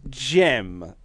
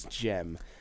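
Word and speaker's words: Cem: But I believe it's Jem. Sorry, but even though it's spelled Sem. – Okay. – I believe it's Jem. – It's a Turkish name.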